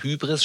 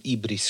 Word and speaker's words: Hybris: Das heißt, wenn man in Deutschland von Hybris spricht, sollte man eigentlich von Ibris sprechen.